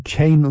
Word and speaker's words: Keane: Von der Frau Monika wird dann nicht Keen ausgesprochen. Nein, wird Kane ausgesprochen. Ich habe extra nochmal nachgehört,